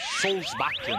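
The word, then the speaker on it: Solbakken: Só mandar um abraço para o nosso querido William Douglas, que me mandou a pronúncia certa do técnico da Noruega. Solsbakken.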